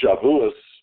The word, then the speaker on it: Shavuot: Shavuot, in Ashkenazi, it's pronounced Shavuos in Ashkenazi usage.